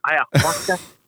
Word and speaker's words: ayahuasca: Where you decided to go down to Mexico to get treatment and take some, was it ayahuasca? – Yeah. I love the, I like your pronunciation. You're like, ayahuasca? You sound like a true Latino there. Ayahuasca?